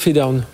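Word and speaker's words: Phedone: Alors Fedown, ça s'écrit P-H-E-D-O-N-E e pour ceux qui nous écoutent en radio.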